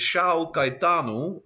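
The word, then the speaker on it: São Caetano: Eh, dus uh, Shao, ik geloof dat ik het officieel moet uitspreken. Shao Caetano zou dan onze, onze club van de week zijn.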